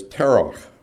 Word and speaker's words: Terah: The word for moon is Yareach. Terah is Terach.